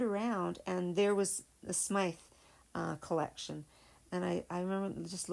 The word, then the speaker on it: Smythe: There's an E on the end, so I'm thinking it's Smythe, not Smith.